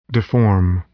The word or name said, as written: deform